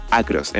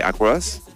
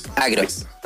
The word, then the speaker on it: Acros: La forma oficial, bueno, Acros realmente es como una palabra más en inglés, pero le intentamos dar como esta cosa más latina, como dándole como el acento en la A, Acros, en vez de Acros.